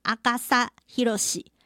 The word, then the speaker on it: 阿笠博士: 阿笠博士他的名字呢日文发音叫做阿嘎萨希罗西。